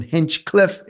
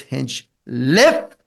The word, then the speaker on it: Hinchliffe: They call him Hinchcliffe. It's not Hinchcliffe. It's Hinchliffe. Okay, L-I-F-F-E.